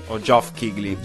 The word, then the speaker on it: Geoff Keighley: Geoff Keighley.